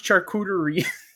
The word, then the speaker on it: charcuterie: Fucking chachere, motherfuckers. – Chachere. It's pronounced charcuterie.